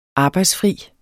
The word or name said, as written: arbejdsfri